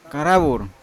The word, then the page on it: karavur: Yawa